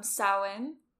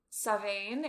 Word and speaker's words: Samhain: Not that I know of, but this is the religion that kind of solidified what we now call Halloween, which is Samhain, if you're Irish. Samhain, if you're Welsh, and don't let me pronounce the Scottish.